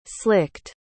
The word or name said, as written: slicked